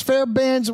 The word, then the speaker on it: Fairbairn: I can't pronounce Fairbairn's, whatever.